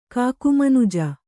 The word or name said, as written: ಕಾಕುಮನುಜ